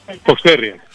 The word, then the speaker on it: Fox Terrier: Fox Tier, ¿cómo es? – Fox Terrier. – Fox Terry.